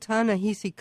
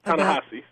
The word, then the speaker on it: Ta-Nehisi: And we're speaking with Ta-Nehisi Coates. – Ta-Nehisi, sorry. – Oh, there again. – That is okay.